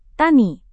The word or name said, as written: tani